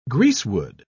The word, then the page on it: greasewood: British English